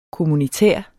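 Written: kommunitær

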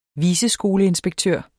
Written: viceskoleinspektør

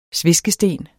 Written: sveskesten

